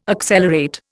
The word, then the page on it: accelerate: Indian